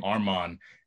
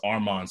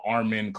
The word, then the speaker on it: Armon: mispronounce Armon so often, call me uh, Armin, call me Alman. They would add a D at the end, like just a lot of different shit. I'm like, it's not that hard. It's the word arm, the word on put together, but people would always mispronounce people always it.